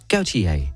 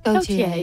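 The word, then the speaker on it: Gotye: Hvordan udtaler man det her? Og der blev så svaret, Gautier. – Gautier.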